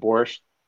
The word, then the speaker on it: borscht: It's pronounced borscht.